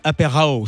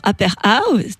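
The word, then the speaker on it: Aperhow: Je le dis mal peut-être hein, avec mon accent anglais, Apérao, ça veut dire quoi? Ça s'écrit comment? – Alors, Apérao, tu as très bien dit, ça s'écrit A-P-E-R, comme le début d'apéro, et ensuite H-O-W, comme how, comme comment, et aussi pour dire H comme healthy, O comme opportunity, et W pour wellness.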